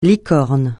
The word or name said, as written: licorne